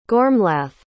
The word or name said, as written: gormlaith